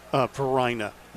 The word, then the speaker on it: Varina: I went to this high school spelled V-A-R-I-N-A. – Yeah. – How do you pronounce it? – Varina.